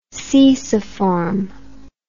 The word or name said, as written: ceciform